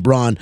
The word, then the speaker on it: Braun: I, I always want to say Braun, and I said it on Thursday, but he pronounces the name Braun.